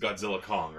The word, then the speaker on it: Godzilla x Kong: Alright, it's just would be just Godzilla Kong, right? Technically. – Yeah, because you don't pronounce the X.